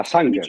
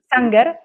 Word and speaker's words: Sanger: Sanger, Dice, sì, sì. Sanger,